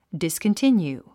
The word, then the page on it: discontinue: dìskəntínjuː; ディスコンティニュー